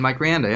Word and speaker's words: Rianda: Our friend Mike Rianda.